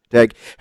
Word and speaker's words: Daigle: Joining us now on SENZ off the back of a convincing win overnight is head coach Justin Daigle, who joins the program.